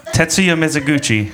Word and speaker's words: Tetsuya Mizuguchi: Tetsuya Mizuguchi.